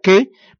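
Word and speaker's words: Ce: ¿Qué? ¿Qué? Que se pronuncia qué, pero se escribe C-E.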